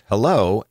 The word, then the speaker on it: HELO: Take the fact that email headers actually have two from fields, H-E-L-O, pronounced hello, and mail from.